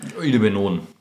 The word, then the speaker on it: Idebenon: Fragetherapie, da hat der Augenarzt wahrscheinlich an dieses Idebenon, Idebenon wie spricht man es aus? – Idebenon.